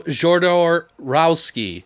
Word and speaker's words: Jodorowsky: Well, I always say his name wrong, but it's Alejandro Jodorowsky, who did Holy Mountain.